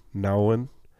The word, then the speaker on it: Nouwen: I think it's Henry Nouwen. – Nouwen.